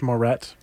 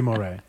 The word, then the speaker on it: Moret: Yeah, it's pronounced Moret. – Moret. – Because the Americans bastardized it, but yeah, it should be Moret.